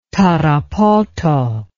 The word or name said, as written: Tarapoto